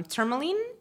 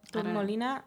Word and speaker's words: tourmaline: Tourmaline. Tourmaline. It's spelled like the tour. – Like tour, yeah.